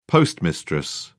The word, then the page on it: postmistress: British English